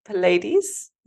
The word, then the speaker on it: Pleiades: And Pleiades. Is that also the right way to say? Mm-hmm. Thanks.